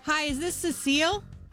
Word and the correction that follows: Cecil: Hello? – Yes, it's Cecil. – Oh, Cecil is how you say your name.